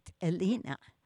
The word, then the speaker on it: Helena: Helena, okay. – Yeah, okay. – Helena. For a soft H sound. – Oh, let's get this right. Helena. – Helena, whatever. – You do pronounce the H, yes.